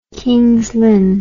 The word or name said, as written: Kings Lynn